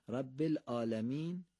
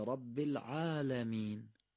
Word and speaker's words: العالمین: رب العالمین یا رب العالمین؟ قطعا دومی درسته. دقت کنیم که حرف عین اون غلظت خودشو پیدا کنه، یه مقدار تکیه کنیم به گلو و غلیظ تلفظ کنیم، عین درست میشه.